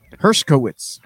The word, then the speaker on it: Hershkowitz: You have to just stop trying to say it because you say it wrong every time. – Hershkowitz. – There you go. You got it. Oh my God. – I said Hershkowitz, didn't I? – Yeah. You always forget the K.